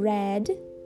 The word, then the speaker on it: red: Presta atenção que é diferente da pronúncia de head. Head é cabeça. Red é vermelho. O R do red é esse r, er, tá bom? Red, diferente de head.